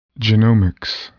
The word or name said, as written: genomics